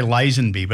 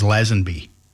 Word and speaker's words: Lazenby: we used to say Lazenby, but after we've spoken to him a few times and he's told us that it's correct, the correct pronunciation is Lazenby.